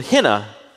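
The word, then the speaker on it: henna (ἵνα): The little word that is the Greek word henna, pronounced like that Asian temporary tattoo.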